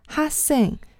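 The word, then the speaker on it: 哈 sing (학생): 但是这里面其实想说的呢，是让大家记住“ 学” 这个字的发音，不要发成。哈 sing 哈 sing 才是正确的发音。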